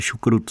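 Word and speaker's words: choucroute: Ono se to píše eh, ne tak, jak to, tak to vyslovuji, ale šukrut se to vyslovuje.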